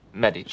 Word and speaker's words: Medici: Say Medici again. – Medici. – Shut the fuck up. – That's how you pronounce it.